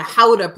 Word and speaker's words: Gouda: I'm going popcorn and Gouda. – Excellent. Gouda idea. How many times can we use Gouda? – Well, if we start also going with the Howda pronunciation, then we can use it a lot.